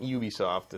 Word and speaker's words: Ubisoft: Yeah, but Ubisoft is officially how they say it.